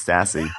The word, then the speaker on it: Stassi: If there's one thing that rhymes with classy, it's Stassi. – And assy. – I will continue to call her Stassi because it sounds like the German secret police.